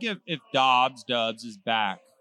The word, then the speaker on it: Doubs: He was Dobbs is not pronounced Dobbs? – D-O-U-B-S. – Okay. – In Nevada, we call that Dubs.